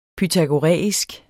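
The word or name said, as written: Pythagoræisk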